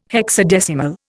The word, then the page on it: hexadecimal: Indian